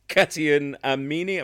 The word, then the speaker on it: Katayoun Amini: was Katayoun Amini. Am I pronouncing that right? Uh, you are. You are. Yeah.